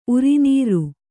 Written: ಉರಿನೀರ್

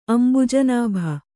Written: ಅಂಬುಜನಾಭ

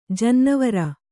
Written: ಜನ್ನಿವಾರ

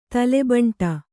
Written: ತಲೆಬಂಟ

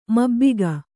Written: ಮಬ್ಬಿಗ

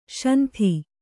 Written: ಶಾಂತಿ

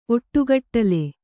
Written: ಒಟ್ಟುಗಟ್ಟಲೆ

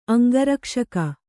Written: ಅಂಗರಕ್ಷಕ

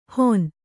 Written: ಹೊನ್

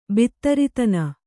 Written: ಬಿತ್ತರಿತನ

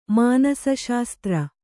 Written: ಮಾನಸಶಾಸ್ತ್ರ

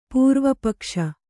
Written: ಪೂರ್ವಪಕ್ಷಿ

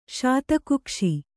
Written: ಶಾತಕುಕ್ಷಿ

